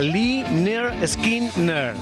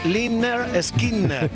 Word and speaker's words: Lynyrd Skynyrd: Ya el álbum de aquel disco se titulaba Pronunciate Lynyrd Skynyrd. Te está diciendo cómo nos llamamos. El disco se titulaba Pronunciate Lynyrd Skynyrd. Somos los Lynyrd Skynyrd. ¿Por qué somos los Lynyrd Skynyrd? Mira, existía un profesor de gimnasia que se llamaba Leonard Skinner.